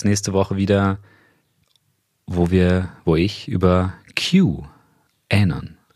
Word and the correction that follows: QAnon: rede ich habe das bestimmt nicht richtig ausgesprochen, aber ich rede über QAnon. QAnon.